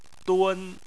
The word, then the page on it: dun: 1